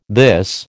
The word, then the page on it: this: American English